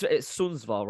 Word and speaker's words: Sundsvall: Okay, so let's speak about your, obviously, since you moved to Sundsvall, it's Sundsvall, right? I'm pronouncing it right, Sundsvall. – Yeah.